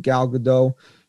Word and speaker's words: Gal Gadot: I didn't think it was a great movie, the original Wonder Woman with Gal Gadot, however you say her name.